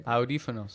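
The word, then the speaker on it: audífonos: But the only one that I learned was adifafonos. Did I say that right? – No. – Damn it. – Slow it down. – Adifonos. – A-U-D. – This, I don't like this word. – Audifonos.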